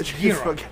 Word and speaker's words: gyro: I my favorite way to have lamb is in a gyro. Am I saying that right? – Oh, yeah, yeah. – Gyro, if you're American. – Gyro, yeah, but it's gyro.